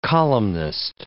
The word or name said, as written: columnist